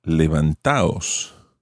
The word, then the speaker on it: levantaos: So to avoid this, and indeed to help with pronunciation, you take out the D, and levantados becomes levantaos, levantaos.